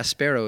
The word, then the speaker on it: Spero: Now, the name of your practice again, is Mount Sparrow, and you don't spell it like the bird. You spell it S-P-E-R-O? – It's not S-B-A-R-R-O like the mall pizza place. – Mount Sabaro, I got a big passion for pizza. – Yeah. – Sparrow, it's Latin for hope.